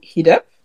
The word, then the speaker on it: Heed-up: Alors, euh, est-ce que tu es la créatrice et la rédactrice du magazine Hit Up Est-ce que j'ai bien prononcé Oui.